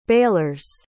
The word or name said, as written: bailers